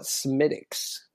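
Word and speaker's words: Smithwick's: They call it Smithix. – No, that's right.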